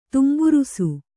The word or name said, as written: ತುಂಬುರುಸು